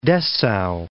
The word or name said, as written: Dessau